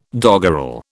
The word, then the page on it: doggerel: British English